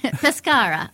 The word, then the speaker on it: Pescara: Pescara. I think they just pronounce it with an R like that.